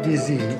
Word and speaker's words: dizi: How do you pronounce the specialty here? Dizi? – What they serve is called dizi, which actually is the name of the pot.